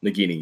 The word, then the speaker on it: Nagini: Nagini, yeah.